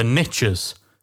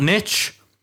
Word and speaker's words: niche: As the saying goes, the riches are in the niches. Once again, I'm going to catch heat for taking the American pronunciation because, of course, in the UK, it's not niche, it's niche. We use the Francais. Pronunciation.